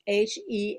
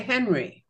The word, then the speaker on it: Henri: And what you brought up about Hélène, yes, the H is silent. Henri would be silent also. This is a man's name, Henri. – Oh, we Henri. – would say Henry, right? – Yes, in English it would be Henry with an H, ha. And in French it would be Henri.